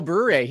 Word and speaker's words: Bure: Pavel Bure? – Pavel Bure.